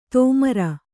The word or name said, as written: ತೋಮರ